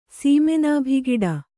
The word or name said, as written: ಸೀಮೆನಾಭಿಗಿಡ